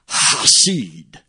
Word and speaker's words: Hesed: H-E-S-E-D. That's how it's spelled. H-E-S-E-D. But it's pronounced Hasid.